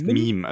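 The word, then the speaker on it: Mîm: Er heißt Meme. Also Meme. ich glaube, im Hörbuch hat er ihn auch mehr Meme ausgesprochen.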